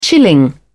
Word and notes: chilling: British English

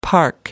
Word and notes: park: American English

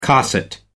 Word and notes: cosset: American English

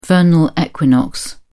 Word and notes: vernal equinox: British English

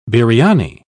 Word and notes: biryani: British English

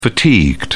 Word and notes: fatigued: British English

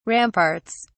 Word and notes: ramparts: British English

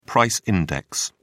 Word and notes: price index: British English

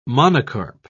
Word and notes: monocarp: British English

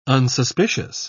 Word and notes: unsuspicious: British English

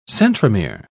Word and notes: centromere: British English

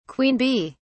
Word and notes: queen bee: British English

